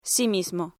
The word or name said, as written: sí mismo